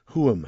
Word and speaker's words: Huam: Going to play a track now from the new Salt House album, which I think is pronounced Huam, H U A M, and the track's called Firelight.